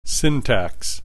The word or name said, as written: syntax